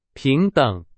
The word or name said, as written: 平等